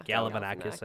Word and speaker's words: Galifianakis: I this Zach Galifianakis. Is that how you say it? Galifianakis? – Yeah, Galifianakis.